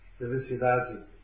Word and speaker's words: Felicidade: In Rio de Janeiro, when they say Felicidade, they pronounce D-A-D as with a Dadi, which is Dadi, Ciudadi.